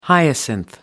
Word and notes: hyacinth: American English

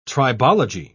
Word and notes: tribology: British English

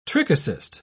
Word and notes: trichocyst: British English

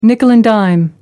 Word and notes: nickel-and-dime: American English

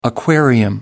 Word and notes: aquarium: American English

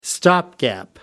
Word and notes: stopgap: American English

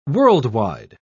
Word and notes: world-wide: British English

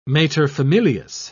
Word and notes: materfamilias: British English

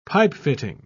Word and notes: pipefitting: British English

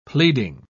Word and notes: pleading: British English

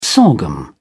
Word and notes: sorghum: British English

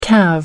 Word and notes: carve: American English

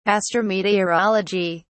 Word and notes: astrometeorology: British English

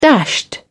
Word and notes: dashed: British English

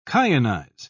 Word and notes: kyanize: British English

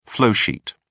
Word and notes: flowsheet: British English

